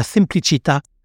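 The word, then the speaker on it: semplicità: la simplicita, avec l'accent tonique sur le A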